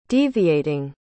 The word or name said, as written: deviating